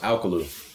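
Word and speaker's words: Alcolu: You start in like South Carolina, Alcola, South Carolina? – It's pronounced Alcalo, actually. It's a little bit different. Kind of got like that Southern slang to it.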